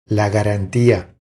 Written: La garantia

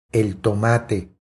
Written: El tomate

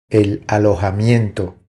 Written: El alojamiento

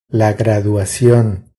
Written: La graduacion

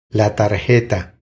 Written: La tarjeta

